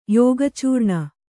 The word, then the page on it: ಯೋಗಚೂರ್ಣ: kannada